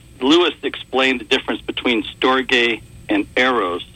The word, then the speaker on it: Storge: Well, the way Lewis pronounced it was Storge.